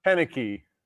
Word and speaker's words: Koenecke: Can you tell them how you pronunciate your last name? – Yeah, it's pronounced Koenecke.